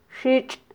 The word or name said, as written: xitx